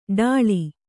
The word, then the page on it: ಠಾಳಿ: ṭhāḷi